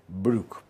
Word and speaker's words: Broek: Broek, que se pronuncia Brook, porque el diptongo OE en neerlandés se pronuncia U, como en Boer, que se pronuncia Bur.